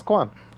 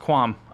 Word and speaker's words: qualm: I've been making fun of you for saying it wrong for so long, because when I read it, I know there's an L in the word. Qualm. But... – It's qualm. – I looked it up. It's pronounced qualm.